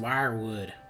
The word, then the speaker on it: Weirwood: The Weirwood? – Dane, it's pronounced Wirewood.